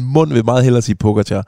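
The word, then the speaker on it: Pogačar: Altså, jeg har lyst til at lige opspille et lille klip, fordi jeg har fandme fået meget røg for, at jeg siger Pogacar. Og jeg ved godt, at han udtales Pogacar, men jeg kan simpelthen ikke lade være.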